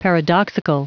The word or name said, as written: paradoxical